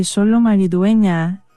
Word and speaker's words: Xolo Maridueña: Xolo Maridueña. – Exactamente, Xolo Maridueña, que yo creo que mucha gente lo conoce por Cobra Kai.